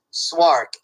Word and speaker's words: Szwarc: Oh, it's Jeannot, J-E-A-N-N-O-T, Swark, S-Z-W-A-R-C.